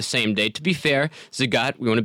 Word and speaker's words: Zagat: You've got the new Zagat Guide. Am I pronouncing that correctly? – You're pronouncing that correctly. It's the Zagat Guide and the Michelin Guide.